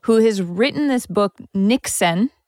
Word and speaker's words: Niksen: Now, this is a woman who wrote an article for the New York Times in 2019 about Niksen, which isn't even really... – It's tough to say. I wish it wasn't pronounced Niksen.